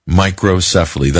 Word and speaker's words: microcephaly: but may have caused microcephaly, that's the correct pronunciation, in newborn babies.